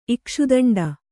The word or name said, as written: ಇಕ್ಷುದಂಡ